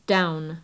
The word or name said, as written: down